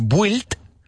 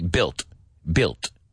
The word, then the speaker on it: built: No es built, la U, no está en esa palabra. Ok, built. Dilo conmigo, built. Lo mismo que build, construir, el verbo construir. Right, build. Nunca pronunciamos esa U, ¿ok? En tu mente, táchala, la U.